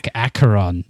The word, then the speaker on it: Acheron: The Acheron. – Acheron. You don't give it the French pronunciation.